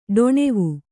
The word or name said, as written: ಡೊಣೆವು